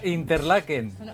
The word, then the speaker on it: Interlaken: A Interlaken. – Interlaken también. – Está bien pronunciado.